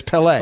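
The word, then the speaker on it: Pelé: I always say Pele, and we just had a caller call in and say that I'm saying it wrong, that it's Pele. – Yeah, that's how I say it, Pele. – It's Pele. – On the second syllable.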